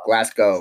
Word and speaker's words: Glasgow: How'd you say it again? Glasgow? Wait, is it? It's not, it's Glasgow.